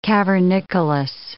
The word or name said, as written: cavernicolous